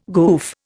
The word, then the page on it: goof: Indian